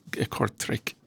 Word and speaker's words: Kortrijk: I've been to Belgium a few times in the past, but it's my first time here to Kortrijk. I pronounce that right. – Oh, very good, very good.